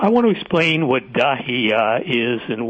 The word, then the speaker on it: Dahiya: Dahiya is a Beirut suburb. I said that right.